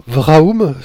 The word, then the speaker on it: Vraoum: Donc euh, les éditions, c'est euh, Varum Vraum, je prononce bien C'est tout à fait ça,